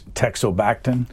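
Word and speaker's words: teixobactin: If the medicine, if I pronounce it correctly, it's Texobactam. – You got it. Correct.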